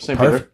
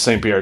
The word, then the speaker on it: St. Peter: Yep, St. Peter. – All right. Or I figured it would be like something French, like St. Peter. – It is. I mean, it's St. Peter.